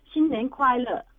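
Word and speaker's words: Xin Nian Kuai Le (新年快乐): Xing Yung Ki Lo? Is that Happy New Year? – I think you're trying to say Xin Nian Kuai Le, which is Mandarin for Happy New Year.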